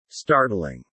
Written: startling

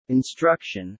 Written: instruction

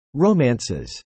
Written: romances

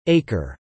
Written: acre

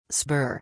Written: spur

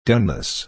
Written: doneness

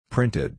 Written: printed

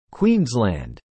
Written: Queensland

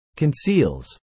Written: conceals

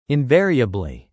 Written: invariably

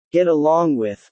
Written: get along with

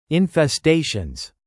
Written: infestations

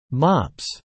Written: mops

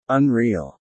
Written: unreal

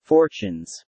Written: fortunes

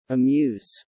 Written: amuse

